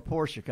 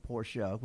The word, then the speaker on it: Porsche: Well, not to use that name, Porsche, which is a two-syllable word, as we say.